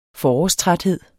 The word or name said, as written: forårstræthed